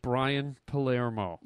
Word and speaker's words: Brian Palermo: I'm here with Brian Palermo.